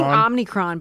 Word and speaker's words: Omicron: I keep saying Omicron, but it's Omicron.